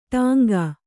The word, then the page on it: ಟಂಕಿ: kannada